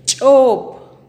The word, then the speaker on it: chop: Piña en pocomá se dice chop. Chop. Repite conmigo. Chop.